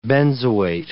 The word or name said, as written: benzoate